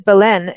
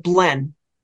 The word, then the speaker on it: Belen: Okay, so I was going to pronounce it Belen, and now I know, because I was waiting for you to bring it up. – And I was going to say, too, that a lot of people in that area pronounce it Blen.